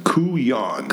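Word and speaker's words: couillon: It's pronounced Kuyan.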